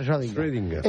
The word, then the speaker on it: Schrödinger: Schrödinger. – Alberto, lo pronuncia tan mal que tú no puedes, no sabes ni a qué se está refiriendo. – El gato de Schrödinger.